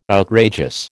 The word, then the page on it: outrageous: American English